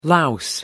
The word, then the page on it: louse: American English